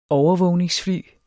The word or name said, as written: overvågningsfly